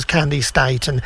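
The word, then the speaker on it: Candi Staton: Candy Staten, or Candy Staten. I think she did arrive at it being pronounced as Candy Staten.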